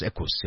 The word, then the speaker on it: Equus: Is that how you say it? Equus. – It's Latin for the word horse. – The horse. Equus, yeah.